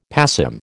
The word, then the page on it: passim: American English